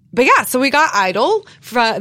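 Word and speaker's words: (G)I-DLE: As we learned last week, they're actually pronounced Idol, spelled G-Idol.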